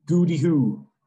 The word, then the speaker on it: Gwdihw: Spelt GWDIHW. Okay, that's how it's spelt. It's pronounced Goody Hoo.